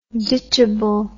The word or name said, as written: ditchable